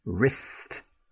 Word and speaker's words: wrist: An unpronounced or silent W is most commonly followed by the letter R. For example, wrong. You got the wrong answer. Wrist on the end of your hand. Right I got the wrong address. I broke my wrist. I'll have to write with my other hand. Wrong wrist right. All with that R sound, the W silent.